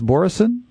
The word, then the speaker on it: Boroson: Is it pronounced Boroson? – That's right.